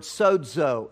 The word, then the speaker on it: sozo: The word save in the Bible, in the New Testament, is a Greek word, sozo, S-O-Z-O, if you want it in the English. And there's a D sound when you pronounce it, if you want to look smart.